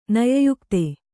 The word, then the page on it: ನಯಯುಕ್ತೆ: naya yukte